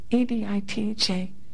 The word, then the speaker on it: Editha: It's called Edith. Um, how did she even pronounce it? E D I T H A, Editha, by Howells.